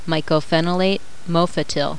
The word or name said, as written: mycophenolate